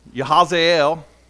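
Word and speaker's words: Jahaziel: A guy by the name of Yahazel, I looked up the kind of phonetic pronunciation, of it, and that's, that's as close as I can get. Yahazel.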